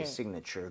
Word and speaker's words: signature: signature. 그래서 앞쪽 I의 강세를 좀 주면서 사실은 signature, 그러니까 살짝 나오지만 실제로는 발음을 빠르게 들으면 거의 signature. 거의 A 발음이 거의 안 나오게 돼요.